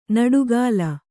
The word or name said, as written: ನುಡಿಗಲ್